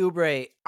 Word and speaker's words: Oubre: I would put number three as like Jalen Green or like Kelly Oubre. Is that how you say it? – Oh yeah, Kelly Oubre.